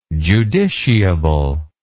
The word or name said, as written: judiciable